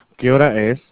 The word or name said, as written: ¿Qué hora es?